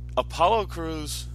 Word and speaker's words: Apollo Crews: And then Apollo Crews and...